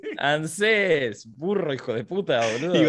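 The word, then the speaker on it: ANSES: Ansés. – Sí, es Ansés. Sí, igual está mal porque no tiene acento en la E, así que no está mal decir Ansés.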